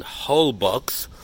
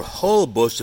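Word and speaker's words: Holbox: We are in Holbucks, or Holbush, if you want to pronounce it right.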